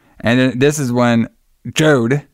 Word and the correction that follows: Job: Am I saying his name right? Jode. – Job. J-O-B. – So it's a Job with the okay. – Yeah, like in the Bible.